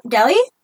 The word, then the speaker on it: Delhi: Delhi? – Okay, Delhi gang rape. Thank you.